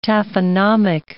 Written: taphonomic

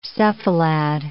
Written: cephalad

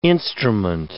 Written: instrument

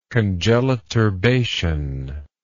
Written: congeliturbation

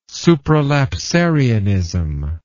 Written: supralapsarianism

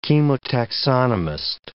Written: chemotaxonomist

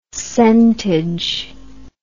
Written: centage

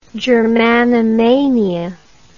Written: germanomania

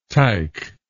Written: tike

